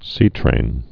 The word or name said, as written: seatrains